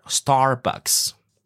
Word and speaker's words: Starbucks: So a lot of people just say S-T-A, Starbucks. You know, no R. – Starbucks. – Ah, very good. There we go. So if you remember the R, Starbucks. Starbucks.